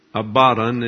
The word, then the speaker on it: Abaddon: Abaddon is the way it's pronounced in the Greek.